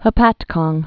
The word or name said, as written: Hopatcong